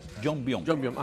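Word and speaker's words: Yongbyon: ¿Es así se pronuncia? Jongbyon, ajá. – Exacto.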